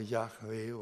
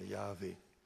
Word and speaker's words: Jahwe: Die Gelehrten sind sich nicht einig, aber die meisten scheinen sich doch einigermaßen geeinigt zu haben über diese Aussprache Jahwe oder Jahwe.